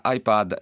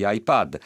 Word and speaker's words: iPad: Innanzitutto una parentesi, devo alla cortesia di un ascoltatore che mi ha mandato una mail la corretta pronuncia di iPad. Io continuavo a dire un po' iPad, un po' iPad, invece un ascoltatore molto gentile, nonché molto più ferrato di me nella linea inglese, mi ha segnalato che la pronuncia corretta è con la A, diciamo, di amica.